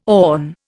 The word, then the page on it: awn: Indian